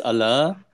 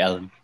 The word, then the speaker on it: Alain: It's a French name, so actually it's Alain, but everyone calls me Alan. It's much easier to pronounce. So you can call me Alan.